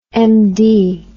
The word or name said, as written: MD